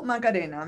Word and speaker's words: Macarena: So my Twitter ID is Farku Macarena, my last name and then my first name.